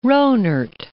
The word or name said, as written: Rohnert Park